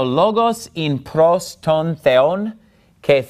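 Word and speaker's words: logos (λόγος): And notice in logos, I'm putting the stress on the first syllable, because that's where the accent mark is. En arhi in o logos, ke o logos in pros ton theon.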